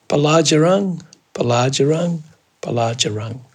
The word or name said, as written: baladjarang